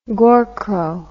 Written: gorcrow

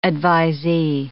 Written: advisee

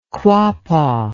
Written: quapaw

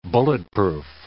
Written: bulletproof

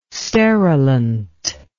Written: sterilant

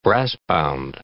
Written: brassbound

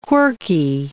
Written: quirky

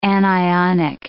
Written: anionic

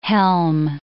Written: helm

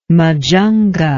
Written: Majunga

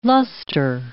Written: lustre

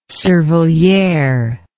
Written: cervelliere

